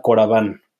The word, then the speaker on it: Coravin: Ahora hay una herramienta que a mí me parece espectacular, eh, que se llama Coraván, que se escribe Coravin con V.